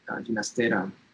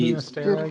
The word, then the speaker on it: Ginastera: One of the big examples of Latin American repertoire we know by Ginastera, Estancia, it's ballet. – Say Ginastera again. – Well, that's always a contentious point. Yes, Ginastera should be, right? The G in Spanish is pronounced as an English H.